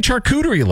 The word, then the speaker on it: charcuterie: Mark Johnston from Play 92, he says, why do you say charcuterie like that? I'm like, what are you talking about? He says, it's not ch, it's sh, it's charcuterie.